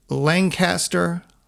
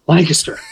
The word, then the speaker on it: Lancaster: But it is, as our drummer says, you got to say it like you're sneezing, like Lancaster.